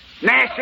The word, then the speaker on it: gnash: Gnash is spelled G-N-A-S-H, and the G is silent preceding the N.